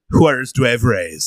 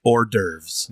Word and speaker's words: hors d'oeuvres: Juarez Duevres season. I love saying it that way. – Can you say it correctly for the people at home? – Commonly known as hors d'oeuvres. – There we go. – Muse Bouche. – Should be spelled O-R-D-E-R-V-E-S.